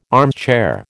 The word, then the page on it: armchair: American English